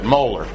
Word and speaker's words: molar: That's how you pronounce it, molar.